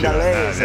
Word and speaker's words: Mandolese: And Kevin Mandolese made 46 saves in his NHL debut to lead the Sens to a shootout win on Long Island. But first, Italian pronunciation. – Mandolese, yeah.